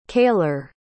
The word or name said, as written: kaylor